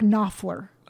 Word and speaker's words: Knopfler: You probably don't pronounce the K, so Mark Knopfler.